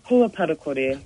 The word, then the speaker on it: Pua Parakore: Now, what is, and I'm going to mispronounce it, Hua Pakori, is that what I'm hearing? – Pua Parakore. – Parakore, so, okay, yep.